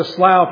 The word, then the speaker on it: slough: Whenever I'm in the slough of despair, or the slough, if you want to pronounce it that way, the slough of despond like Christian was, and cannot find the stepping stones, if you know the story.